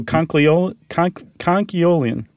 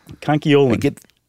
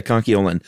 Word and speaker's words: conchiolin: Or, oh, conchiolian. – Yeah, you're throwing that L in there. – Oh. – It's very easy to want to do. – Conchiolin. – I get the urge. Yeah, conchiolin. And nacre, by the way, is spelled N-A-C-R-E.